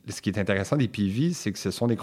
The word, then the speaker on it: PIWI: PV, ça s'écrit PIWI, et c'est effectivement un acronyme pour des cépages résistants aux maladies.